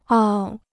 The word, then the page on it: 傲: ào